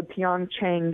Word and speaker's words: Pyeongchang: And it's called, it's pronounced, is the P silent, Pyeongchang? – Pyeongchang.